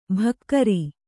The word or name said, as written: ಭಕ್ಕರಿ